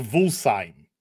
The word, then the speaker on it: Wolfsheim: Yeah, it's pronounced Wolfsheim. That's how you pronounce it.